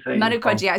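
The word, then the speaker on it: Manu Codjia: You can correct my pronunciation. – Manu Codija.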